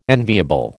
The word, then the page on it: enviable: American English